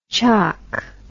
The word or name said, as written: choc